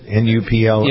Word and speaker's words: Nuplazid: Is it Nuplazid? Is that how you pronounce it? Nuplazid? – Yes.